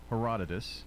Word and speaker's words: Herodotus: So our categories today are history according to Herodotus.